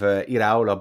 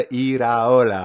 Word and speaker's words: Iraola: And sorry, I got the pronunciation wrong. Yeah, I knew you were going to correct me. You do that really well, actually, without saying to me, no, Mark, you got that wrong. You give me the proper pronunciation. I love it. – Good, yeah. Shall we practice it together? Iraola.